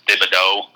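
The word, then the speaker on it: Thibodeaux: And you know, they've got the kid who was one of, if not the top recruit in the country last year, Kayvon, I believe it's pronounced Thibodeau, if I've heard that correctly on TV.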